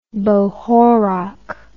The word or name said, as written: bohorok